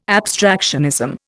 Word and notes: abstractionism: Indian

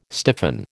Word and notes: stiffen: American English

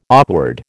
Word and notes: awkward: American English

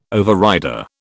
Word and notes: overrider: British English

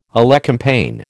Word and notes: elecampane: American English

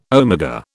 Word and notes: omega: British English

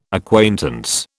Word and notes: acquaintance: British English

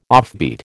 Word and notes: offbeat: American English

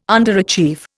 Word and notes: underachieve: Indian

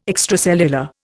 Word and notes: extracellular: Indian

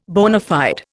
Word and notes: bona fide: Indian

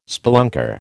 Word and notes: spelunker: American English

